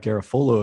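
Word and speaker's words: Garafolo: And then we had the report from Mike Garofolo of NFL Network. And yes, that is how it's pronounced. Tristan's not here to hear that, but I was correct.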